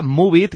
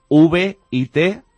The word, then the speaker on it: Moovit: Pues vamos a recordar, Mónica, Moovit, que se escribe MOOVIT, Moovit, es totalmente gratis y además se puede descargar para cualquier dispositivo móvil.